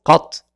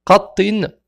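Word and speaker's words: qat (قط): C'est pour ça qu'on prononce qat. L'enfer dira qat, qat.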